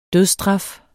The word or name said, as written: dødsstraf